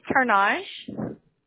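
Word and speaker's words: Turnage: That's going to be Tarnage. – Could you spell it? – T-U-R, N as in Nancy, A, G as in giraffe, E as in elephant.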